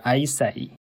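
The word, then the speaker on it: 衛生: 最后他就想到了庄子里有卫生这个词，他的日文读音就是 aisei。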